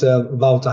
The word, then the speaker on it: Wouter: It's Wouter Hazebroek.